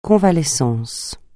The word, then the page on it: convalescence: francais